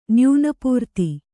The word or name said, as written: ನ್ಯೂನಪೂರ್ತಿ